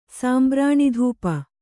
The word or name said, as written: ಸಾಂಬ್ರಾಣಿಧೂಪ